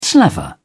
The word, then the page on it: slaver: British English